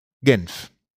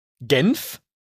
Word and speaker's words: Genf: Genf. – Genf? – Mhm. – So wie Senf mit G?